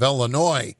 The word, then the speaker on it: Illinois: We've got people in Michigan who pronounce the S at the end of Illinois.